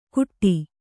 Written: ಗುಟ್ಟಿ

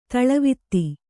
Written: ದಳವೆತ್ತು

